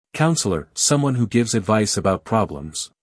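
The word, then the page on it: counsellor: uk ˈkaʊnsələ(r); en-us ˈkaʊnsələr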